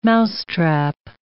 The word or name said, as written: mousetrap